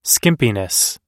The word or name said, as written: skimpiness